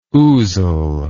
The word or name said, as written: ousel